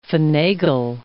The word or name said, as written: finagle